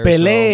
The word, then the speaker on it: Pelé: I believe it does have the accent on the E there. – Pelé.